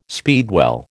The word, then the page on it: speedwell: American English